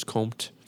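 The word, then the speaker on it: Comté: I think I learned yesterday you don't pronounce the E, so it's Comte.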